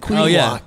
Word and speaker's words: Quinnipiac: Quinnipiac. – Quinnipiac.